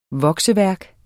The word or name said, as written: vokseværk